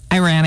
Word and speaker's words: Iran: Is that how you say it? – Iran, you got it. Not Iran, Iran. – Okay, Iran.